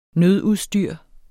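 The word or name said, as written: nødudstyr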